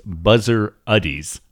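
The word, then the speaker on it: Bezzerides: Also, turns out I'm probably pronouncing his name wrong. It looks like, now that I'm seeing a pronunciation guide, it's Buzzer Uddies. – Okay. – Not Rides. Uddies.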